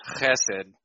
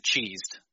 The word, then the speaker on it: chesed: You have to get the phlegm going in the throat, right? It's chesed. I had one teacher who pronounced it cheesed on purpose, just as a joke.